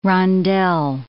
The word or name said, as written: rondelle